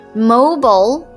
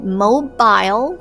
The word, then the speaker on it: mobile: 注意一下 mobile, 两个读音 mobile 或者 mobile.